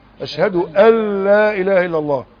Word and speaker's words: أشهد أن لا إله إلا الله: الكلمة التي تدخل بها الإسلام لا تجد نطقها، هي تكتب أشهد أن لا إله إلا الله، لكن تنطق أشهد أن لا إله إلا الله، إضغام بدون غنة.